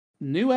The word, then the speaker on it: Nue: The first puzzle is a room with a Nue, I think is what I wrote. Poison gas. – Yeah, that's pronounced.